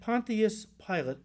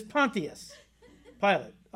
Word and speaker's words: Pontius Pilate: He said, "Pontius Pilate," and that is the correct way to pronounce his name, by the way. Pontius is not right. You want to call him Pontius? That's up to you. I'm just telling you, the right way to pronounce his name is Pontius Pilate.